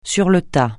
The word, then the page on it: sur le tas: French